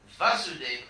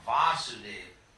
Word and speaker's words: Vasudev: Vasudev with a short A, Vasudev is Krishna's father. With a long A, Vasudev, that's Krishna.